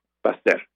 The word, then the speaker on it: Pasteur: el colegio francés Pasteur, Pasteur, pero se pronuncia Pasteur.